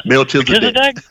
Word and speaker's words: Melchizedek: Truly a priest of the highest order of, I know I'm going to butcher this name, Melchizedek. I could never say that right. – Melchizedek? – That's it.